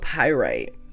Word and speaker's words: chalcopyrite: How do you say that? Chalcopyrite. Chalcopyrite. You heard her.